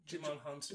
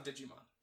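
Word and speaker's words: Djimon Hounsou: How do you say his name? – Jimon Hounsou. – Jimon Hounsou. – It's not Digimon.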